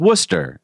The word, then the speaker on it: Worcester: and Worcester is Worcester, and Gloucester is Gloucester, and Boston.